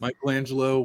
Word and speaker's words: Michelangelo: Like Michelangelo, one whole name.